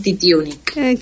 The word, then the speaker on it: Titiunik: Lo pronunciaste perfecto y te agradezco un montón. – ¿En serio? – Estoy muy acostumbrada a tener varias adaptaciones de mi apellido. Incluso en gente que me conoce por años. – Mirá. – Me gustó como el pequeño segundo que te tomaste y sí, es Jazmín Titiunik.